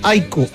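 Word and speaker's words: AIK: Oiga, un buen amigo que está en sintonía, Marcelo, que vive en Suecia, dijo: no tengan inconvenientes, no se compliquen, díganle Aiko al equipo, pronúncielo como el Aiko.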